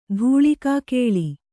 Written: ಧೂಳಿಕಾಕೇಳಿ

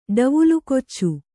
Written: ಡವುಲುಕೊಚ್ಚು

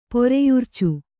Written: ಪೆರೆಯುರ್ಚು

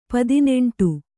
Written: ಪದಿನೆಂಟು

